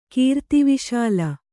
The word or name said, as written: ಕೀರ್ತಿವಿಶಾಲ